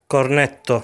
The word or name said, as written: Cornetto